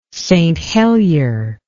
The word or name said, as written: St Helier